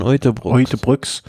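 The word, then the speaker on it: Uijtdebroeks: Wie spricht man ihn aus? Ich bin jetzt den holländischen Namen nicht. – Kian Uytebrücks.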